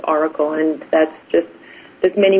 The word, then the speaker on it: Orykl: So it is Oracle, but it's spelled O-R-Y-K-L.